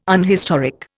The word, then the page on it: unhistoric: Indian